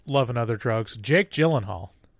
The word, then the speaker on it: Gyllenhaal: He says, "It's Dutch." And so the correct pronunciation is Gyllenhaal.